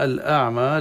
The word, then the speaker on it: الأعمى: هنا الأعمى العين ساكنة، دائما أنا أنبه على هذا المسألة، إن البعض نطقه للعين الساكنة أشبه بألف، الأعمى هكذا أعمى ولا أعمى أو أعمى.